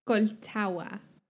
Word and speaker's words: Colchagua: so you, you don't really pronounce the G. – Colchagua, like the D is kind of you. You mean like the, yeah, yeah, yeah. You you don't. – So Colchagua.